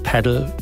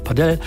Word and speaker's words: Padel: Korrekt, die meisten sagen Padel, manche sagen aber auch Padel, weil es wohl iberische Wurzeln habe.